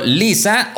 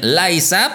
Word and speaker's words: Lysa: A fin de cuentas, ese asistente supuesto nunca fue oficial, pero Huawei parece que sí ha hecho oficial a este asistente llamado Lisa, o algunos me dicen que se debería llamar o pronunciar más bien Liza, porque tiene L-Y-S-A.